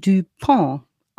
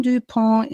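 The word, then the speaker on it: Du Pont: Auf Französisch ausgesprochen, Amerikaner, gebürtig französischer Abstammung. Seine Vorfahren waren Hugenotten und sind vor der Revolution aus Frankreich nach Amerika geflohen. Also so eine alte Familie sind die Duponts. Der John Dupont im amerikanischen Dupont ausgesprochen, ich sag mal Dupont, ich krieg's irgendwie anders nicht hin, wird geboren im November 38 in Philadelphia, ganz im Osten der USA.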